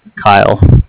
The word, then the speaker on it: Kyle: So my name is Kyle, K-Y-L-E.